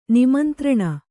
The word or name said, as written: ನಿಮಂತ್ರಣ